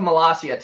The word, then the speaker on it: Malaysia: So, the Republic of Malaysia, tell us, are we saying that correctly? – Yeah, actually you are.